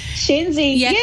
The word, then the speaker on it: Shenzi: Shenzi? – Shenzi.